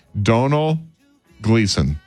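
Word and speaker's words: Domhnall Gleeson: It says you pronounce his name like tonal, but with a D. Donal Gleason.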